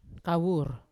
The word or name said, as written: kawur